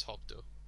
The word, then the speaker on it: Hokuto: Why is it Hakuto? Hakuto? Is that? I've always pronounced it as Hokuto.